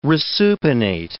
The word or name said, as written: resupinate